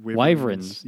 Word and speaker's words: wyverns: How do you say it? – Wyverns. Yeah, wyverns.